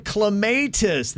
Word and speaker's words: Clematis: It is supposed to be called clematis. That is actually how you pronounce it.